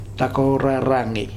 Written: Takoraragi

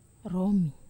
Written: romi

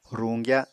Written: ruñgya

